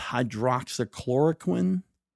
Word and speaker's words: hydroxychloroquine: It's hydroxychloroquine.